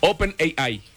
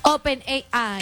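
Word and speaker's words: OpenAI: Open AI. – AI, ajá. – AI. – Open sí. AI. – Okay. ¿Cómo sí, se dice? – Open AI.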